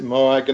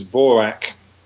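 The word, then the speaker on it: Mhòrag: I mean, we would pronounce it Morag, and Morag is now a fairly familiar Scottish girl's name, actually. But the correct Gaelic pronunciation is Vorak.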